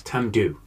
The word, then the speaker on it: Tamdhu: Note my complete lack of an E on the end of the pronunciation. It's Tamdhu.